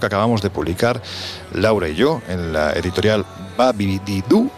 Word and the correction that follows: Babidibu: No, ya lo he dicho mal. Babidibu. Así. Babidibu hay que decirlo rápido para, para, no confundirse.